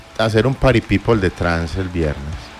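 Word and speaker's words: trance: No, trans, así se pronuncia.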